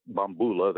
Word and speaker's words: bambula: In New Orleans, the rhythm that they call bambula is bambula, they call it in New Orleans, accent on the second syllable, was the rhythm that it was the Antillean beat.